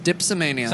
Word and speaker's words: dipsomania: It's called dipsomania.